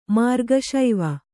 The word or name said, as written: ಮಾರ್ಗಶೈವ